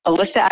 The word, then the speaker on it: Alyssa: And I'll tell you this, I am the only Alyssa Agrifoglio on the planet.